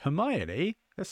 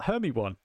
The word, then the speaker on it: Hermione: yeah, no, that's how I that's how I read those books until the first movie came out, and I was like Hermione, they're saying her name wrong. Um, it's spelled Hermy one.